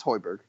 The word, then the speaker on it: Højbjerg: It's Højbjerg. – It is Højbjerg? The hard G? Yes.